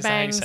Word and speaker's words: Banges: Dervish and Banges. Is bangs. – That how you say it? – Dervish and Bangs.